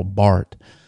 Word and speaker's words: Barth: The first represented by the late Swiss theologian, I believe died in the late 1960s, a man named Karl Barth. I think you say his name is spelled Barth, but I think you just say Bart in the Swiss German.